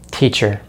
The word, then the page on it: teacher: American English